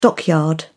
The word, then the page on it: dockyard: British English